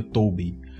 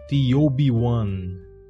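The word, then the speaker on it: T0-B1: Eles pronunciam o nome dele dessa outra forma, né? Que é T0B1. Só que o zero em inglês também você pode pronunciar como O. No caso, a letra O, né? Como aqui a gente pronuncia 6-6 e por aí vai. E aí eles dizem T-O-B-1.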